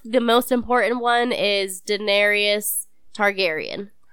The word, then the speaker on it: Daenerys: Jon Snow, and I might be saying this name wrong. Daenerys? – No, you got it.